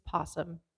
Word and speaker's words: opossum: They're, it's possum. The O is silent. – Virginia opossum. – Oh my God. – What do you know? What do you know about opossums? – I know it's spelled with an O, but you don't pronounce the O.